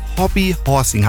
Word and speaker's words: Hobby Horsing: Ich habe gerade gesagt, Hobby Horsing. Habe ich schon mal richtig ausgesprochen? – Ja, jetzt war es richtig. Hobby Horsing heißt es.